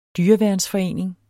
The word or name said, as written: dyreværnsforening